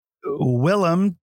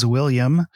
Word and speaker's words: Willem: And then Willem Dafoe, which... regularly gets mispronunciated as William. So I'm surprised I got Willem right.